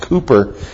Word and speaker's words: Cowper: William Cooper, it looks like Cowper, but it's pronounced Cooper.